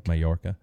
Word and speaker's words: Mallorca: Mallorca.